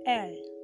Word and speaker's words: elles: And then for a group of girls, we say E L L E S. Okay, which has been pronounced as what? L.